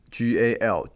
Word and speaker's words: Gal Gadot: GALGADOT Gal Gadot，她就是标准的以色列的美女。